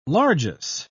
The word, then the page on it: largess: British English